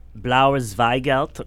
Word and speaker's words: Blauer Zweigelt: Third wine is Weinzer Krems Blauer Zweigelt Rosé 2014. – Wow. Very good German pronunciation.